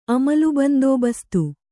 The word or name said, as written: ಅಮಲುಬಂದೋಬಸ್ತು